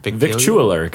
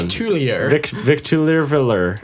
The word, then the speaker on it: Victualler: Yeah, Victular. Victualler, guys, come on. Victualler.